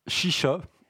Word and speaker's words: Xixa: C'est le groupe Shisha. Mais alors, ça s'écrit X-I-X-A. Donc, l'album s'appelle Xolo, X-O-L-O.